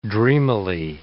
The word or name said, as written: dreamily